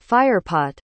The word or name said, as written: firepot